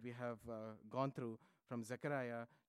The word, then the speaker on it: Zechariah: Zechariah. Zechariah. That is the right way of pronouncing it in Hebrew.